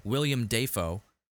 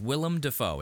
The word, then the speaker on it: Willem Dafoe: Dude, his name is Willem Dafoe. It's spelled out Willem Dafoe. Yet there's that video of him when he was like 19 announcing his name as William Dafoe.